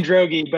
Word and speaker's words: Droege: It's Ryan Droege, but I would say that 1 out of 1,000 people get that right. So you are good. – Yeah, it's a tough call on the hard G when you're just like, when you're reading it sight unseen. You're like, do I lean into that one?